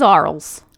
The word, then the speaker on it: Arles: It's a city in France, so it's probably not the way it looks. It is Arles. That's the way you said it. Yeah. Arles.